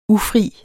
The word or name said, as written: ufri